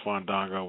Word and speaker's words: Fandango: The chat room said I said it wrong. It's Fandango.